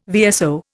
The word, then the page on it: VSO: Indian